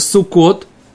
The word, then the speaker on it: сукот: У нее пишется так, оно произносится сукот.